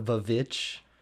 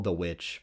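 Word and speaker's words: The VVitch: Oh, I think it's just called the witch, but it's sometimes it's spelled VV, but it's I think it's an Ari Aster one.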